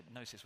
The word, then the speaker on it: gnosis: So we get our English word agnostic, gnosis with a silent G.